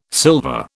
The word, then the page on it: sylva: British English